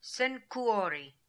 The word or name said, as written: Senkoo ori